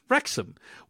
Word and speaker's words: Wrexham: The W in Wrexham is silent. It's just Wrexham, which is only funnier as they say Wrexham no less than 15 times in the first episode alone.